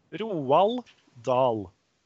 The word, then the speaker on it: Roald Dahl: Roald Dahl. – I thought you were joking. – No! – Roald Dahl? – Yes! – Say that again. – Roald Dahl.